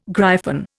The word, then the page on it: gryphon: Indian